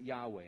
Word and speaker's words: Yahweh: We pronounce it Yahweh. It has no vowels in the original pointings, the original text.